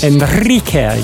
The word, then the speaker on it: Enrique: Is that how you say it, Iglesias? – Enrique. You need to let it roll. – I've never been able to do that. – Well, roll. Yeah. – Roll your R's.